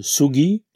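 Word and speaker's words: sugi (杉): L'idéogramme japonais qui désigne cet arbre et qui se prononce sugi, cet idéogramme représente la forme d'un arbre et puis trois fossiles parallèles.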